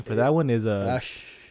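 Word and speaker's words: Æ: I think X is just X, And then AE is supposed to be Ash. That's how you're supposed to pronounce it, Ash. I think Ash.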